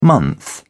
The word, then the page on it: month: British English